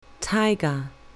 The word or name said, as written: tiger